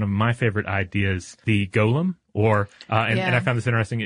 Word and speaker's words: golem: It can also, since this is a Jewish idea, it can be pronounced goylem. – Mm-hmm. – which is, I guess, more in keeping with the Hebrew.